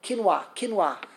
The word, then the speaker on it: quinoa: Quinoa.